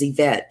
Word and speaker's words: Evett: I was using my middle name, which is Yvette. And it's not even spelled traditionally. It's spelled E-V-E-T-T, which is Evett to some people.